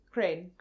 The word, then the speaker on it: Karain: It only has one syllable. Karain? – Karain. – Yeah, less you, just Crane.